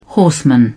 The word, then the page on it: horseman: British English